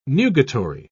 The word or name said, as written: nugatory